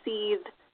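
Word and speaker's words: seiðr: it's pronounced seethe, cedar, cider, depending on what country you're coming mm-hmm. – from. Cider is Norwegian. And that's my ancestry, so I choose to call it cider.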